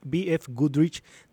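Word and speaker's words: BFGoodrich: al confirmarse la edición número 60 de la BF Goodrich 3 SCORE Baja 1000, lo dije bien, Elga.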